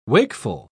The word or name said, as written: wakeful